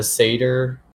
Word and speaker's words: satyr: Oh, a satyr? – Yeah, a satyr. – That's how I pronounce it. It's S-A-T-Y-R. Satyr. Yep, it's satyr.